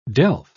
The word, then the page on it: delf: British English